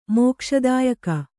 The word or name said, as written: ಮೋಕ್ಷದಾಯಕ